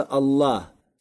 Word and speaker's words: Аллах: Поэтому говорится не «аль-иля», а говорится «Аллах».